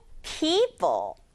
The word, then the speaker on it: people: p, e-o지만 e-만 소리 나요. 그 다음에 p, o 소리 안 나는 e. people 빠르게 하면 people. people 요렇게 발음할 수 있죠.